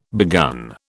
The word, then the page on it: begun: British English